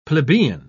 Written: plebeian

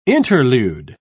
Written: interlude

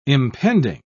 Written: impending

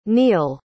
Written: niyol

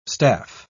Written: staph

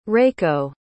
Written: reiko

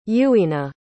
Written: Yuina

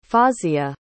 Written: fawzia

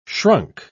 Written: shrunk

